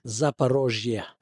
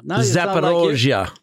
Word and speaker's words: Zaporizhzhia: Residents near Zaporizhzhia plant. Am I pronouncing that right? – Zaporizhzhia. – Zaporizhzhia plant. – There you go. – Zaporizhzhia. – Now you sound like Ukrainian.